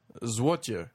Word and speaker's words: złoty: For 10 zloty, zlotya is how they would... – Zlotya? – Yeah, it's spelled Z-L-O-T-Y, but I think they would say zlotya.